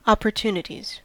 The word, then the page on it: opportunities: American English